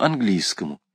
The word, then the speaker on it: английскому: В обиходе таких семей, как наша, была давняя склонность ко всему английскому. Это слово, кстати сказать, произносилось у нас с классическим ударением на первом слоге.